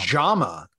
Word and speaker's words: Jaume: And, you know, I feel like what the director, is it Wame? Is that how you pronounce his first Jaume. – Name? Jaume. Jaume. Thank you.